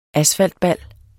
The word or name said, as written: asfaltbal